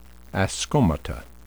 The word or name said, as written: ascomata